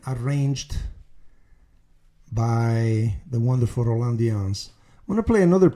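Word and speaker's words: Dyens: His last name is spelled D as in Douglas, Y-E-N-S, Dianz, Roland Dianz.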